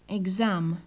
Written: exam

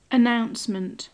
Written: announcement

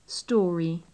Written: storey

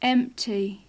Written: empty

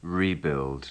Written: rebuild

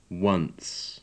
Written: once

